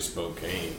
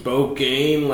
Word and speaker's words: Spokane: I say Spokane. – Yeah. – Spokane, Washington. – Mm-hmm. Spokane like a true American.